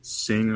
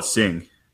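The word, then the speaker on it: Singha: Have you guys had Sing, or it's spelled like Sing Ha? – Ching Tao?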